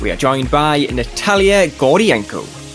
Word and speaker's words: Gordienko: I enjoy Gordienko, and it also is one of those few Eastern European surnames which is pronounced exactly as it's spelt, which is very, very enjoyable.